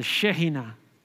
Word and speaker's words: Shekinah: Y cuando estás en el lugar, estás mirando a la nube, estás mirando al Shekinah de Dios, al Shekinah, creo que se pronuncia en hebreo.